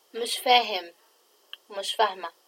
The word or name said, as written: mishfaahim